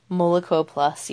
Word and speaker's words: Moloko Plus: Moloko Milk Plus. Did I say that right? – Moloko Plus, yeah.